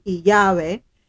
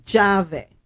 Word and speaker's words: llave: It sounds like a Y, right? In some countries. Now, in the Dominican Republic, the LL doesn't sound like a Y. It's pronounced like a J. So instead of saying Yahweh, they say Jave.